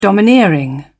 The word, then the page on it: domineering: British English